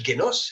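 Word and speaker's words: génos (γένος): Fuet, croissance, et on va retrouver cette racine euh, indo-européenne, gène, gné, dans le mot génos, génos, enfin on prononce génos, c'est le gamma, génos, naissance, descendance, lignée, race, ce qui a été engendré par quelque chose.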